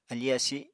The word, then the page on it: afi asi: Apatani